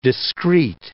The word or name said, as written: discreet